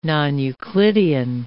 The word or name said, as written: non-euclidean